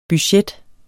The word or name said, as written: budget